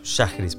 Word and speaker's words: Schajris: Shahris, perfecto.